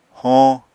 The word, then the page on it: hoñ: apatani